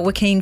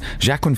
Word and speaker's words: Joaquin: which is going to star Joaquin Phoenix.